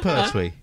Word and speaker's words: Pertwee: I said Pertwee.